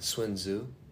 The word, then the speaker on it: Sun Tzu: Do you know anything about Sun Tzu? – I might pronounce his name Sun Tzu.